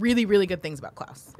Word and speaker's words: Klaus: The choices are How to Train Your Dragon, The Hidden World, I Lost My Body, Claws, Missing Link, Toy Story 4. – Really quick, I think that's supposed to be Klaus. – What's that? – It is Klaus.